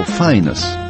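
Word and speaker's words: Phineas: В Ветхом Завете он был сыном пророка Илии и братом Офни, но друзья-джазмены отказывались от лишней гласной и звали его Файнас. Он и сам предпочитал быть Файнасом. Леонард Фезер в своей статье 61 года указывает: «Финиас ратует за то, чтобы его имя произносилось как Файнас с ударением на первой гласной».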